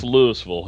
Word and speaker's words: Louisville: Well, I know it drove you crazy, Chris, but it is pronounced Louisville here where I live.